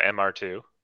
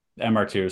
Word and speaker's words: MR2: MR2. Some people call it the Mr. 2, right? I've heard of Yeah, both. Right. – So the pronunciation MR2 in French, MR2, merdier, which means excrement or feces.